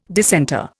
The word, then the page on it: dissenter: Indian